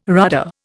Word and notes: rudder: Indian

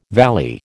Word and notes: valley: American English

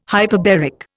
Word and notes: hyperbaric: Indian

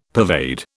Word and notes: pervade: British English

